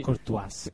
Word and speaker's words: Courtois: No, porque está Thibaut Courtois, si se pronuncia así. – Courtois.